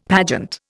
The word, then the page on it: pageant: Indian